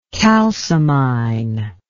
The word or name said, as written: kalsomine